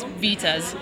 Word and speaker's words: Vitez: I've heard Vitez. It's Vitez.